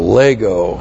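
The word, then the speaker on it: Lego (λέγω): L-E-G long O, Lego.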